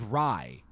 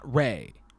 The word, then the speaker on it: Ray: And I heard his last name pronounced as Rai, not Ray.